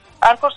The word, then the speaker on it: Anchor: Y está, pues lo tenemos en la página, pero en Anchor, Anchor se pronuncia así.